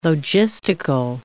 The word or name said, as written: logistical